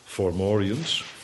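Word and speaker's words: Fomorians: Zo schrijf je dat. Zo spreek je het niet uit, maar Formorians. Onder leiding van ene Partolan.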